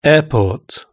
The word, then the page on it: airport: British English